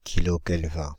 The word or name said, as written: kilokelvins